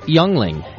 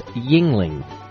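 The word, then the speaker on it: Yuengling: Youngling is not pronounced youngling. It's pronounced yingling. Thanks, Don. Now, I appreciate the correction. He's from Pennsylvania. It's spelt youngling, but I guess you're supposed to say yingling, which sounds much more Asian to me.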